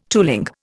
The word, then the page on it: tooling: Indian